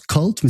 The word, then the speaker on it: CVLT: Ça se prononce Cult, mais ça s'écrit C-V-L-T.